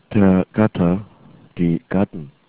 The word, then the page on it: gatte: german